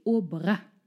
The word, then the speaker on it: Aubrac: Heute sprechen wir über die wunderbare Lucie Aubrac. Liebe Katrin, wer war sie? – Übrigens, hinten das C spricht man mit.